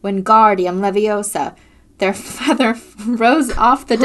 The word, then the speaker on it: Wingardium Leviosa: It's Wingardium Leviosa. You say the gar nice and long.